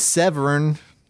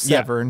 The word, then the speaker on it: Severn: This guy's name is Severn, which is not me pronouncing seven weird. His name's Severn.